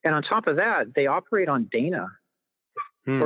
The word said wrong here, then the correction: Dana: Or I'm sorry, I guess it's pronounced Donna.